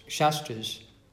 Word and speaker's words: Shastras: And we can see here how the Shastras, with a long A, are describing things that happened millions and millions of years in advance from here, and they will come to be.